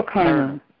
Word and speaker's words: karna: How do you spell karna? – K-A-R-N-A. – K-A-R-A-N-A? – No, K-A-R-N as in Nityananda, A. – Oh, karna. Uh-huh.